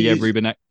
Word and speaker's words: Rubinek: It's the whole thing is actually, I mean, Saul Rubinek, I presume I'm saying that right. Rubinek. – I would say, Rubinek.